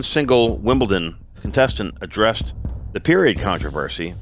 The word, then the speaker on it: Wimbledon: Wimbledon. Yeah, that's how you pronounce it.